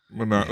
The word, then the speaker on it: Manon: Is that how it's pronounced, Manon? – Manon, that's in the audiobook, Manon, is what they say.